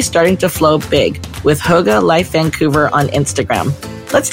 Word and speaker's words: Hygge: So it's more like hue, like a color is a hue, and ga, like a baby would say. So Hygge.